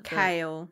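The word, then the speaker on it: Chaol: How did you pronounce his name? – Kale. – I think it'll like kale, like the kind of the green stuff you eat, kale.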